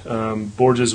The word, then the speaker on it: Borges: Last name is Borges. Is that right? – Borges? – Oh, yeah.